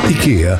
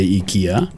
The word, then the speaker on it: IKEA: It's two different pronunciations. – It's regional. – Mm-hmm. – Well, I get if you're Swedish, you'd say IKEA.